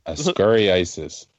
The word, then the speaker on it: Ascariasis: Ascari ISIS. Here, Popsicle, I'll just spell it for you. A-S-C-A-R-I-A-S-I-S.